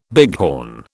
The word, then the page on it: bighorn: British English